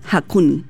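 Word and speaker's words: Haakon: Oye, no, la muerte del monarca en el fondo activa automáticamente la sucesión y ahora asume su hijo Hakon.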